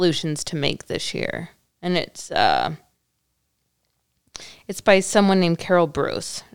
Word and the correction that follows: Bruess: Carol Brees. – Brees. How did you know her? – It rhymes with peace in her bio.